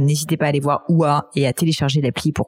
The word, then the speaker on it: OOHAAH: C'est enfin ça se prononce OUA et ça s'écrit O O H A A H.